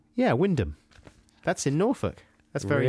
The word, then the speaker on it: Wymondham: Wyndham.